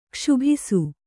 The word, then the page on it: ಕ್ಷೋಭಿಸು: kṣōbhisu